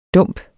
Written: dump

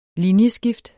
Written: Linjeskift

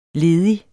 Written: ledig

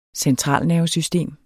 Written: centralnervesystem

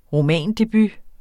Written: romandebut

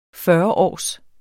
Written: fyrreårs

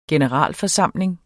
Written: generalforsamling

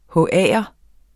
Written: HA'er

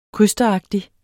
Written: krysteragtig

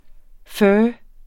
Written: pho

